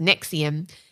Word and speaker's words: NXIVM: This week we started watching The Vow on HBO, which is a look into Scientology group called NXIVM, pronounced Nexium, and it is extremely engaging and frightening.